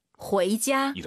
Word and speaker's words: 回家: UI 사이에 에 넣어서 후에이 들어볼게요. 집에 가다 발음만 回家。 – 이렇게 하는 거예요.